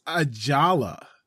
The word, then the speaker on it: Ajala: Oh, I just want to say, and I'm sorry, I don't know is his last name Ayala? Ajala? I'm not sure how to pronounce it. – I think it's Ajala. – Ajala? – Like the J isn't silent.